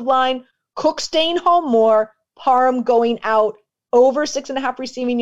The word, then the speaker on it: Parham: But I'm going over 6.5 receiving yards for Donald Parham. Am I pronouncing that properly? – Yep. – I don't know. Parham, you got it.